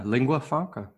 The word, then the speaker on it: lingua franca: data are made as a lingua franca. I'm not sure how this is pronounced. How do you pronounce this? – Yeah, lingua franca.